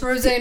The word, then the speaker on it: Rosé: How you pronounce her name. Yeah, it's Rosé, not Rose.